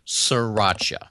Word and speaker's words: sriracha: He says, sriracha. – Sriracha, like sriracha. Yeah. – Sriracha. So that first R is kind of silent.